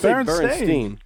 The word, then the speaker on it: Berenstain: No, it's Berenstain. But it's spelled. It's spelled Berenstain. Right. – Yes. Is it really? – Yeah, like a carpet, like a stain on the carpet.